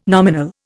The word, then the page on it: nominal: Indian